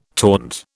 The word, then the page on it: taunt: British English